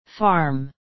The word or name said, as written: farm